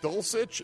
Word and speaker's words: Dulcich: And good pronunciation as well. Greg Dulcich. It is.